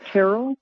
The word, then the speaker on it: Terrell: It's like Carol with a T, so it's like Terrell.